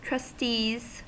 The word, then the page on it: trustees: American English